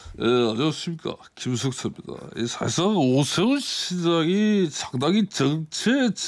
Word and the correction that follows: 절체: 증명의 위기에 발음이 안 되네. 절체 증명의 절체 발음이 이렇게 어려워요.